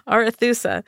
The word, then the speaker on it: Arethusa: So the Arethusa, I keep putting extra syllables in this. – That's fun. – His boat yes. – could carry... – The Zarathustra. – Right? That's what... Arethusa.